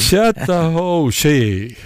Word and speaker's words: Chattahoochee: Hur uttalar man Chattahoochee? Hej. – Chattahoochee.